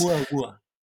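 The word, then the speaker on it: Umamua (ʻOumuamua): I'm gonna say this wrongly, umamuma shapes. – Umamua. – Yeah. Okay. Umamua. Say that one more time.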